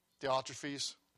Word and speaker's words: Diotrephes: Diophanes, I'm sorry. Wasn't it Diophanes you talked about? Diotrephes. So I had that written in my Bible from a long time of study about certain men crept in unawares in the church.